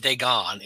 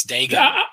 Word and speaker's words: Dagon: Temple of Dagon, not Dagon. It's Dagon.